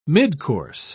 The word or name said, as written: midcourse